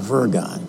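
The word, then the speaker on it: wyrgan: Worry comes from the Anglo-Saxon word virgon, W-R-Y-G-O-N, virgon. It's pronounced virgon. The W is pronounced with a V, virgon, which means to strangle.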